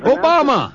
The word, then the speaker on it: Obama: And listen, what I was thinking about is this guy, Obama. – It. Yeah. – It's easy to remember. It rhymes with Osama and your mama.